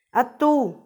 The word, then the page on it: attu: Macuiltianguis